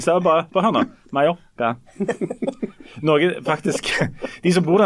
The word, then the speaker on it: Mallorca: Du, eh, jeg har vært i, på Mallorca, som jo uttales med en J istedenfor to L-er, fordi at spanjoler ikke kan verken stave eller uttale ting.